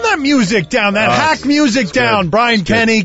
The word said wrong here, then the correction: Kenny: The Gambler, Kenny Rogers. – I mean, he's so good. I love him so much that I got his last name wrong. It's Brian Kenny.